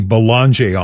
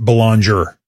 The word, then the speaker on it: Boulanger: Because when Buckley was first elected a Liberal MP, we were informed by his people that the last name should be pronounced Boulanger. Now, I had for years pronounced it Boulanger. They said no Boulanger. I asked him this morning. He says, either way, Boulanger is fine. Some people say it Boulanger.